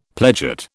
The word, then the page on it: pledget: British English